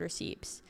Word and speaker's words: receipt: Like, Booth testifies that she was basically at this mall where the boy was taken, based on credit card receipts, and I pronounced the P on accident. – I've never heard anyone pronounce the P in receipt.